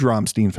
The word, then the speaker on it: Rammstein: You're a huge Rammstein fan.